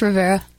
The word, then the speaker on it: Rivera: And since I've mispronounced one name, did I mispronounce the other? – You got it. It's Rivera.